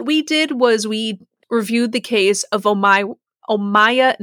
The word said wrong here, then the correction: Omaima: Nelson. Thankfully, I put pronunciations on every page. – Omaima. – Omaima? Okay. Omaima. So I put the wrong pronunciation on every page, is what you're saying.